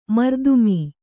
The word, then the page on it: ಮರ್ದುಮೀ: mardumī